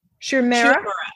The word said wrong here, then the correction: Shu Uemura: Shu Uemura.